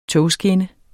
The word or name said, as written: togskinne